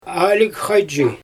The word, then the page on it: aalyg xajzhi: Xyzyl